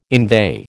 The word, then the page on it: inveigh: American English